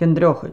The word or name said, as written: KINDROCHID